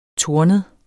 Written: tornet

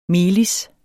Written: melis

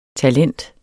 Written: talent

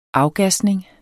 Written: afgasning

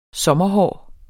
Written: sommerhår

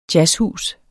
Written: jazzhus

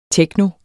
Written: techno

